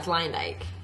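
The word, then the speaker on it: Luyendyk: Okay, also, Chris Harrison butchered his name. – It's Leyendijk.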